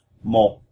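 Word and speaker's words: một: So mo is how you say one. That's M-O-T with a symbol that goes down and it stops in your throat. So even though the spelling ends in a T, we say it with a P sound in this case.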